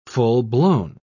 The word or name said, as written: full-blown